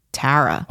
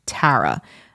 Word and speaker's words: Tara: They thought it was spelled T-E-R-R-A, which it's not. It's T-A-R-A. But it's just I have like that nasally East Coast pronunciation of Tara. There it was either Tara or Tara.